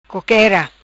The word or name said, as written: Coquera